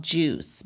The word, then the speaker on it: Jousse: So I use a line called Juice, but it's actually spelled J-O-U-S-S-E.